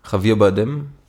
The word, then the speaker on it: Javier Bardem: Und wenn ich weiß wieder nicht genau, Javier Bardem.